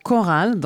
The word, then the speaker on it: Khoral: oui, alors je vais vous parler d'un groupe qui s'appelle Choral. Donc ça s'écrit K-H-O-R-A-L et en fait qui est un trio, comme ils disent.